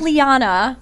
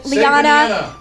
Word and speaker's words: Liana: My name is Liana. – Liana. – Yeah. Once it's in your mind, you remember it. But Liana rhymes with banana, okay?